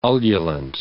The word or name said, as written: ululant